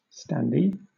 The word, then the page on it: standee: British English